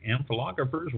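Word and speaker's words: ampelographers: But there are people, amphilographers, who would. Amphilographers, and that's spelled AMPELOGRAPHY or PHERS, are experts in amphilography.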